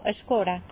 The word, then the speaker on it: escola: Sì, però in cambio vostè sap com ho diem nosaltres, vostè diu escola però... Després vostè em diu que aquí dieu escora, no? – Escora, sí.